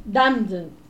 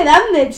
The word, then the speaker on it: Damned: Vimos Village of the Damned. No, pero no se dice Damned, se dice Damned.